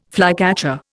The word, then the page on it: flycatcher: Indian